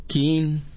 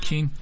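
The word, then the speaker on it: k'in: Kin. No, así se pronuncia. Ya chequé en maya. Sol. – Lo dije bien, Kin. Sí.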